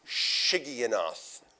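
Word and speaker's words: Shigionoth: In fact, I didn't even pronounce it correctly. It's Sigionoth. It's probably not even correct in pronunciation.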